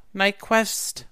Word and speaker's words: Nyqvist: What's the Q sound like? – Quist. – What's the V for then? – Nyquist.